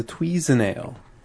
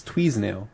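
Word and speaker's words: Tweason'ale: So I had to fill it out, and I saw this new Dogfish Head beer, the Tweezenail. It's, it rhymes with seasonal. It's Tweezenail. And it's for between every season.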